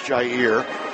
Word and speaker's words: Jhiear: Being sponsored by LaCal Equipment is Jair Malvo, J-H-I-E-A-R, pronounced Jair, last name M-A-L-V-E-A-U-X.